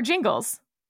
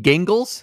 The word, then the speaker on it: Gingles: Purcell or Jingles? – You mean Gingles? – But you pronounce it Jif, don't you? – Well, that's only because I lost a bet.